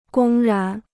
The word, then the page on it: 公然: gōng rán